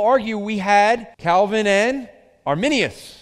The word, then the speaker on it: Arminius: So let's look at the Calvinism of Jacob Arminius.